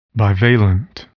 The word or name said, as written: bivalent